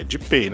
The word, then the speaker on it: Depay: E o Depey né? Memphis Depey da holandês Depay. lá. Joga muito. Não, é Depay, né? Depay é a pronúncia Depay, errada.